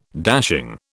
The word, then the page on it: dashing: British English